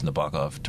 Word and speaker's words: Nabokov: which is the querulous squawk of the heron at night prompts Nabokov to write.